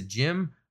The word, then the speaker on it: GM: But as an official designation, it's a gym. Of course, it's spelled GM, but pronounced it as it goes against everything I learned in kindergarten.